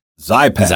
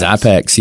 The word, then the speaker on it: Xypex: This is spelled XYPEX. – Mm-hmm. – I believe it is Zypex. – Zypex.